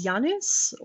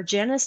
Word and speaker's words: Janus: My last name is Janus, or Janus, depending on how you pronounce it. It's the Roman god of endings and beginnings.